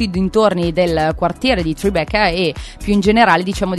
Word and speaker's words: Tribeca: Ma secondo te si dice Tribeca o Tribeca? – Secondo me bisogna dirlo con l'accento Tribeca.